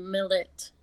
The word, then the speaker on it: Milat: Because the way it's spelled, it's M-I-L-A-T. – Yeah. – I guess a lot of people say Milat. Mm-hmm. But I guess it came out that you actually pronounce it Millet. – Really? – Like with the E pronunciation.